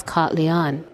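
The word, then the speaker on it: K'alyaan: Many people just say Cat Leanne, and I have heard that one of the corrector pronunciations is Cat Leanne.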